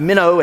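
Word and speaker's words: meno (μένω): The connotations, there's a Greek word. It's pronounced meno.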